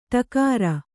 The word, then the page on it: ಟಕಾರ: takāra